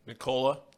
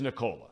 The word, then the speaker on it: Nikola: Now, how do you pronounce this guy's first name? – Nikola. – It is Nikola.